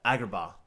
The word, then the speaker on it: Agrabah: Agrabah.